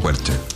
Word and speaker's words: Quercia: ¿Cómo se pronuncia? ¿Quercia? ¿Quercia? ¿Qué? – Yo lo pronuncio como Quercia.